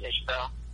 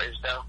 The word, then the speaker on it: Isbell: Actually, more of the throwback country stuff, like Jason Isbell. Or Isbell, I'm sorry. He's pronounced his name.